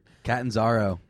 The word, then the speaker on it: Catanzaro: The jackass Arizona Cardinals field goal kicker, Castananzo or whatever. – Catanzaro. – He doesn't deserve to have his name pronounced correctly ever again.